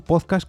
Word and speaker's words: podcast: Un podcast del inglés podcast, aquí ojo que empieza con la palabra un podcast con acento en la O,